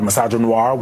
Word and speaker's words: misogynoir: A lot of misogynoir, which is... Misogynoir. – Yes. – Okay. – Instead of misogyny, it's misogynoir.